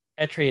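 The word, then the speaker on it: Eitri: It is Etri. I don't know if you pronounce it Etri or not, but it's E-I-T-R-I. – Sound right. – Etri it is.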